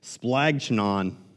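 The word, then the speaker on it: splagchnon (σπλάγχνον): He uses a word that's pronounced splagchnon. Splagchnon. It literally means entrails.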